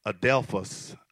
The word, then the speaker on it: adelphos: In its very oldest sense, the word And it's actually pronounced Adelphos in the Greek.